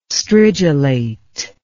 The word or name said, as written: stridulate